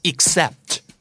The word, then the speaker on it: except: ex ออกเสียงเป็นอีกนะครับ ik เลยครับ except